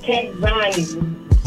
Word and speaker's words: Kechzani: My name is Kek Zani. – Who? Spell that, man. I'm out here with you, saying. – K-E-C-H-Z-A-N-I. – Kek Zani? – Kek Zani.